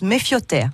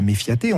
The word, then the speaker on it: Mefia te: Alors en occitan, euh, le A se prononce O. Voilà, donc euh, méfioté s'écrit méfiaté. C'est sujet à débat d'ailleurs, euh, parce qu'on nous dit, mais on dit pas méfiaté, on dit méfioté.